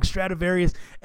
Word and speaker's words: Stradivarius: See, I even said it wrong. Stradivarius.